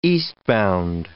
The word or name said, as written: eastbound